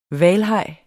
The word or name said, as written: hvalhaj